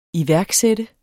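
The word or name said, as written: iværksatte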